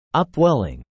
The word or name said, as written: upwelling